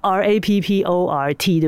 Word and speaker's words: rapport: 那这个 rapport 这个建立 R A P P O R T 对不对？但是那个 T 不发音嘛，rapport。